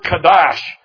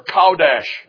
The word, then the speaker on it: kadash: It's the Hebrew word kadash, or kaudash, if you pronounce it in English. Kadash.